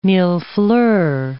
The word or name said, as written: millefleur